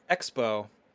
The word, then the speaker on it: XPO: Next up, Skybound celebrates 10 years with their virtual Skybound XPO. I'm sorry, Expo. I didn't pronounce that correctly.